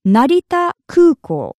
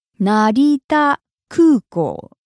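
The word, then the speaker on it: Narita Kūkō (成田空港): First of all, in Japanese, Narita Airport is Narita Kūkō. Narita Kūkō. Let's break that down by syllable. Narita.Kūkō. Narita is the name of the airport, and Kūkō means airport.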